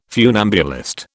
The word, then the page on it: funambulist: British English